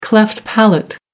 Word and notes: cleft palate: American English

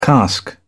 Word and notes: cask: British English

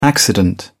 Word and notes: accident: British English

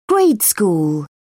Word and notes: grade school: British English

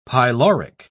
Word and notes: pyloric: British English

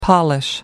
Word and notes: polish: American English

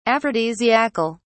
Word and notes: aphrodisiacal: British English

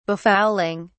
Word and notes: befouling: British English

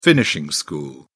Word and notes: finishing school: British English

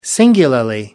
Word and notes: singularly: American English

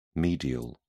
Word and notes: medial: British English

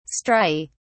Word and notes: striae: British English

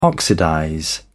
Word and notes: oxidize: British English

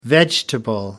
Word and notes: vegetable: American English

